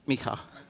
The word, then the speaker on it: Micah: He said, oh, you mean Micah? I said, yeah, Micah. That's how you say it in Hebrew.